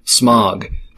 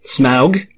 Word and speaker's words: Smaug: May I introduce you to the red prototype, Smaug, pronounced Smaug. Smaug? I did it wrong. Smaug. Smaug.